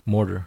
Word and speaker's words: mortar: Is it M-O-R? – M-O-R-T-A-R. Motor. – There's an R in there, dude. – Motar. – Mortar. – Mortar. – How do you say motar? If it's an R in there. – M-O-R, more. – Right. – Tar, T-A-R. – Tar. Mortar. You said motor.